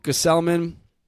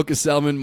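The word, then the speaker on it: Gsellman: I would demote Gesellman. Did I say that right? – Yeah. – I would demote Gesellman, Montero, Salas, bring up some new young arms and take their place on the 40-man.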